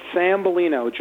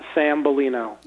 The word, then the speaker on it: Sambalino: It's Sambalino. John Sambalino.